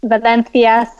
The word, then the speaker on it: Valencia: Yes, I'm calling from Valencia, Spain. – Nice pronunciation. – That's how they say it here.